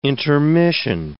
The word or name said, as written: intermission